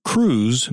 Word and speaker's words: Krewes: Carnival and Mardi Gras happen in the way they do because civic groups called crews make it happen. Crews, which is spelled K-R-E-W-E-S, started out as sort of secret societies and outgrowths of men's social clubs, which were generally selective and exclusive.